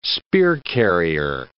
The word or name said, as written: spear-carrier